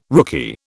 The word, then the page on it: rookie: British English